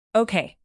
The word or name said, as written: okay